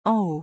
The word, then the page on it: en haut: French